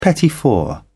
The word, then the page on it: petit four: British English